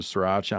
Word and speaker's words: Sriracha: And you bread and fry that fillet, and you put it on your hoagie roll with pickles, onion, lettuce, sriracha. Am I saying that right? – Yeah.